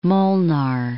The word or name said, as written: Molnar